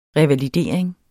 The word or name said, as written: revalidering